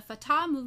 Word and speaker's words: Fatah: F-A-T-A-H, Fatah, that's how I say it. Is that right? – Sure.